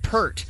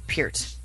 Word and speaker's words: Peart: you pronounce it Pert. No, you don't. His name is Neil Peart,